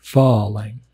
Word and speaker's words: farang: It's Falang. The Thais say it more with the emphasis on the first syllable, like Falang, Falang, like that, Falang.